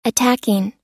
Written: attacking

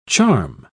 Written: charm

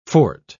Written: fort

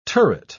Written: turret

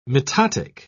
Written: mitotic

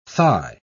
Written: thigh

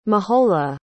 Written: mahola

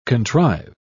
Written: contrive

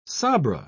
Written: sabra

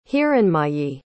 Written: hiranmayi

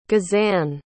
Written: gezane

